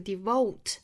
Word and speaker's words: devote: devote 5 minutes，这个 devote 我们注意这个 o 的发音和英式发法的 o devote 要区分开，美式的话呢发成 o。